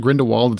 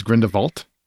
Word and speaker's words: Grindelwald: Am I saying it wrong? It's not Grindelwald, it's Grindelwald. – That's how they say it. I don't know why they say it like that, but they say it like Grindelwald, like Volt, like we're French or something.